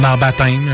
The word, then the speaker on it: baptême: Comme baptême, le P, tu le prononces pas.